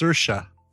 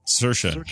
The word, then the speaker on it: Saoirse: And Irony Man says it's pronounced Saoirse. – Saoirse, got it.